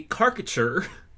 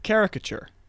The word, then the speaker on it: caricature: It's a caricature of Principal Dimley. – Whoa, that's not how you say it. Caricature.